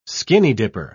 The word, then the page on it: skinny-dipper: British English